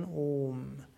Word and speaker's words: Om: Om wiederholst du relativ lang, ist also nicht Om, sondern Om.